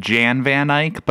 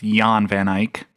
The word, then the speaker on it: Jan van Eyck: It's spelled J-A-N, like Jan van Eyck, but I'm told it's pronounced Jan van Eyck.